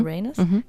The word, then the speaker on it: Uranus: Uranus auf Englisch ausgesprochen klingt wie, oder wird erstmal ausgesprochen, Uranus, war es richtig? Mhm. Uranus, genau. Und wenn man sich ein bisschen Zeit lässt, dann klingt es wie your anus.